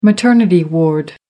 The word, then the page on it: maternity ward: American English